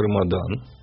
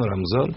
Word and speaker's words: Рамадан: А, не, но если вы говорите про само имя его, ну, вообще в арабском оно произносится как Рамадан. Вот потом уже у нас здесь в других языках появились варианты Рамазан, Рамзан.